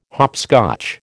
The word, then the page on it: hopscotch: American English